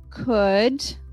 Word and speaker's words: could: o Another area for the silent L would come after an OU. So examples would be could, would, should.